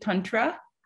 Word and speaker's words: Tantra: So let's switch gears slightly here to back to, am I saying it right? Is it Tantra? – Yeah, you got it.